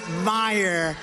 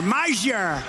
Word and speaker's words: Meijer: Ja, hier moest ik a guy who spells his name M-E-I-J-E-R, but they pronounce it Meyer. The hell kind of a spelling is that? Meyer. Meijer. It's Meijer, but it's actually pronounced Meyer. I said, how the hell do you pronounce this guy's name? Nobody knows him. He's done nothing in Washington. I said, how do you pronounce his name? Is it Meijer? Meijer? They said, it's Meyer. How the hell do you get Meyer out of it?